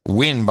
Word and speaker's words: Nguyen: It is Lanvi Nguyen, spelled N-G-U-Y-E-N. Lanvi Nguyen from Scottsdale, Arizona. This is our first. – I think it's pronounced Nguyen. – No, it says it right there. Nguyen behind it. – Oh, okay. Nguyen.